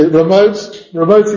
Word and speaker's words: remote: Sí, remote y making con man. ¿Ya? Porque es remote. No es la primera letra, sí, sí, la sílaba remarcada.